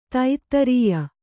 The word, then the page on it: ಧಾತ್ರೇಯಿ: dhātrēyi